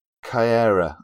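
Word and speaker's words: Caiera: And then you do have this very strong relationship between Hulk and Kyera, I think is how it's pronounced. – That's how I pronounce it.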